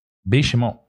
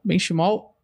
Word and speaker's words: Benchimol: Negro, fala um pouco sobre como é ser sócio da grande Benchimol. É essa a pronúncia? – Benchimol. – Benchimol.